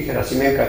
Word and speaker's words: Gerasimenko: aunque se escribe con O, como en ruso no existe la O átona, se convierte en A y es Gerasimenko. Churyumov es un astrónomo ucraniano, y Gerasimenko también,